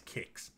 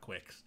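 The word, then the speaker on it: Qix: And it will be taking on number 67, Kix, which I spelled Q-I-X. I had to look up to see how it's actually pronounced. It is Kix, not Quix.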